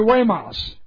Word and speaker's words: Guaymas: He stopped by the village on Tuesday afternoon on his way to Guaymas, and I knew how to pronounce that only because I've been there. G-U-A-Y-M-A-S. I'd have torn that word up. But I learned how to say it. It's Guaymas.